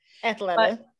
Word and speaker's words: Athleta: Athleta. – Athleta, is that right? – Yeah.